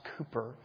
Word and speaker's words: Cowper: It's spelled C-O-W-P-E-R, but it's pronounced Cooper.